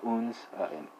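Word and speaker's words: unser: Uh, well, that's uh, unsa, right? But uh, the way I pronounce it, you can't really tell that there's a r. So how do you spell that pronoun? It's u n s e r, right?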